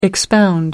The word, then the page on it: expound: American English